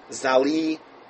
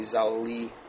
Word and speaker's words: Zalewa: make it to Trudy Zaliwa. Now, it's actually Zaliva, I guess, but it's a silent V. So I'm going to spell it out for you. T-R-U-D-Y. Trudy. T-R-U-D-Y. Last name, Z-A-L-E-W-A.